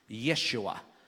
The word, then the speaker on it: Yeshua: It's important to understand that the word Joshua in Hebrew is pronounced Yeshua, or in Greek, Jesus.